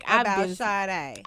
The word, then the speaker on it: Sade: It's Sade. It's not Sade. I've been saying Sade for ever.